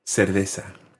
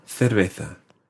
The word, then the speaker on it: cerveza: Now, this is a word that is definitely pronounced differently in different countries. You might hear una cerveza. – Una cerveza. – And that would be a Latin American pronunciation in certain countries. Cerveza. – Cerveza. – In Spain, cerveza.